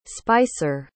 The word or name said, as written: spicer